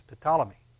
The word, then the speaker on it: Ptolemy: Ptolemy, General Ptolemy. And as he takes over this particular region, you spell that with a P, P-T.